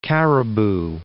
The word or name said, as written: Cariboo Mountains